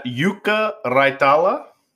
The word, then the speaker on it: Jukka Raitala: Yeah, so it's Jukka Raitala, who is a left back out of Finland, obviously.